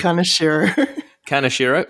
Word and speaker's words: Kaneshiro: Kaneshiro.